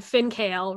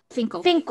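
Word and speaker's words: Fin.K.L: So DSP had, they had Finkale, right? Is that how you say it? – Finkel. – I don't like that. I would rather say Finkale.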